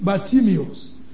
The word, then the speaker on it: Bartimaeus: We know him in the Bible as blind, in the Bible as Bartimaeus. Many pronounces it Bartimaeus. Either way, we'll be alright.